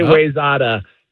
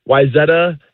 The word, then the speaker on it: Wayzata: Many people say Wayzata. Wyzetta is correct.